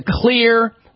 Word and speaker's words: Xlear: Try the Clear Max, M-A-X, nasal spray. Stuff's amazing for people with allergies and stuffed-up noses and runny noses due to allergies. Clear is spelled X-L-E-A-R, the X in the Clear name.